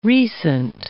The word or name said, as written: recent